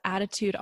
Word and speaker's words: Adaatude: So my name is Adaletta, and I'm known as Attitude Online, but it's spelled A-D-A-A-T-U-D-E because A-D-A-T-U-D-E was taken by someone back in the day.